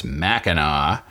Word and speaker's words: Mackinac: Only today, Chris from Michigan sent me a note reminding me that the strait at the top of Michigan is pronounced Mackinac, even though it's spelled Mackinac.